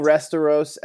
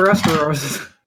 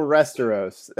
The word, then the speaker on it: Westeros: You mispronounced the W as an R. – That's exactly what happens. I say, who will rule Restoros?